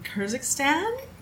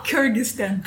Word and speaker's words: Kyrgyzstan: And she is originally from Kyrgyzstan. – Close. – No? – Kyrgyzstan. – Kyrgyzstan. Yes. See, I butchered that. Oh, well. – It was a blend of Kyrgyzstan and Kazakhstan, you said. Kyrgyzstan.